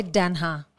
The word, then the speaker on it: Denha: Okay, Denha. – Yeah. – So just like it's spelled. – Oh, it is. – The same way, Denha.